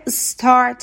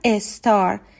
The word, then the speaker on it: star: مثلا لغت S که star تلفظ میشه اما خیلی از ایرانی ها میگن استار یعنی قبل از اس یک ای هم اضافه میکنن میگن استار